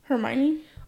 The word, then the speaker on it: Hermione: I did not know that was how you pronounced her name. Like, I just learned not too long ago that's how you pronounce her name. I was saying Hermione.